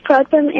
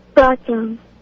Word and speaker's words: Pratham: My name is Pratham, and I think that the answer is Korean. – Is that Gautam? – Pratham. – How do you spell it? – P-R-A-T-H-A-M.